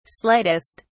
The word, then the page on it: slightest: British English